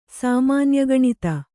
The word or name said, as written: ಸಾಮಾನ್ಯಗಣಿತ